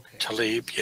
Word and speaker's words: Tlaib: Tlaib, yeah.